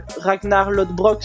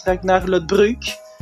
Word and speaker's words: Ragnar Lodbrok: parce que normalement Ragnar Lodbrok se prononce Ragnar Lodbruk, et ça, c'est pas euh, mis dans la, VF.